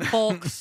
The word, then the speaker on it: folks: Folks. – Folks, what's wrong with that? – And yolks. – It's mouthy. – Yeah. – Folks. – You're mouthy. – Yeah, because you don't pronounce the L.